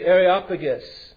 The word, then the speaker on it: Areopagus: And so Paul stood in the midst of the Areopagus. I'm probably mispronouncing that. How do you say that? Where's Demas or Angelo? How do you say it? Yeah, just like that.